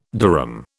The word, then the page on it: durum: British English